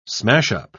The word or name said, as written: smashup